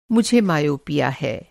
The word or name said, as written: मा